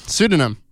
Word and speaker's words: pseudonym: See, that's tricky because it starts with a P, but I know that it probably sounds like an S. – Well, what does it sound like? How do you pronounce it? – Pseudonym.